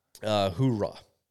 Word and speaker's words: hoorah: Hoorah. – So there's an R. – Yes, and if you say it like hua, that would be an army term.